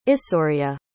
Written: issoria

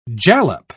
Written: jalap

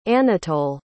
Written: Anatole

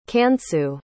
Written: Kansu

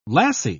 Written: lassie

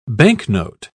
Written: banknote

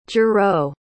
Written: juro

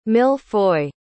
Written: mille-feuille

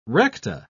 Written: recta

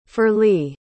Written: ferlie

